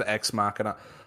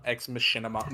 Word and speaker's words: Ex Machina: I think that, especially with Ex Machina, I don't know. I feel like I say it wrong every time. Ex Machina, Ex Machina, Ex Machinima. Not Machinima.